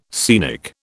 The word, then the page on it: scenic: British English